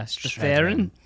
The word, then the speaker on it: Strathairn: Yeah, Strathairn.